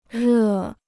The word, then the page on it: 热: rè